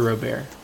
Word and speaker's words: Robert: Is it Robert or Robert? You might be able to tell me how to pronounce it. Robert. – Okay, so it is Robert.